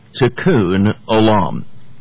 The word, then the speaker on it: Tikkun Olam: It's the phrase Tikkun Olam. Tikkun Olam, it's spelled T-I-K-K-U-N, new word O-L-A-M.